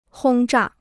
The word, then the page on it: 轰炸: hōng zhà